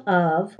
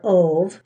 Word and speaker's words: Ove: It's called A Man Called Of, or Ove, depending on how you pronounce it. I pronounce it Of as in love, but some people pronounce it Ove because I think that's probably grammatically more correct.